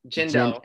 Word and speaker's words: Jindo: His dog is a Shindo. It's a Korean breed of dog. – However, no, Jindo. – Shit. It's a Jindo.